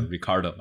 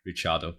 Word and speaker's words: Ricardo: 他自己有时候是自己称 Charles，就我觉得这个名字的话，主要就是你自己的名字自己想怎么读怎么读，大家应该根据就是名字的主人怎么读，所以说大家管他叫 Ricardo 而不是 Richardo。